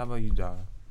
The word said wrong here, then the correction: Deonna: I said Donna, but I forgot the E.